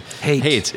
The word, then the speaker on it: Haight: Haight. – It is Haight, right? – Opposite of love, just like in San Francisco.